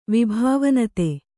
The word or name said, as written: ವಿಭಾವನತೆ